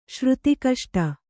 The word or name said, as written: ಶ್ರುತಿಕಷ್ಟ